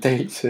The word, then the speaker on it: Tales: Hay que pronunciarlo bien porque si no puede llevar equívoco. – ¿Por qué? – El Tales este. Tales. – Historias de piratas, no colas de piratas.